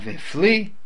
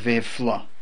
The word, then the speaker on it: v'hiflo: V'hiflo. Not v'hifli, and not v'afle, but v'hiflo.